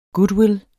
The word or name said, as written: goodwill